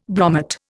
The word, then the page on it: bromate: Indian